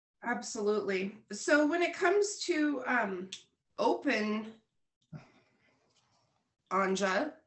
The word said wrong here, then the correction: Ajna: I'm still probably saying Ajna. Ajna. I'm gonna say it like real Ajna.